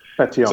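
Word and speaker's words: Pétion: Is it Petion? – Petion. Mm-hmm.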